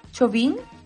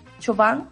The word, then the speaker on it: Chauvin: ¿Cómo se dice? Chauvin. Chauvin. Chauvin, sí eh, esta es una buena noticia dentro de todo lo negativo que es esto también, porque eh, lo que pasó es triste.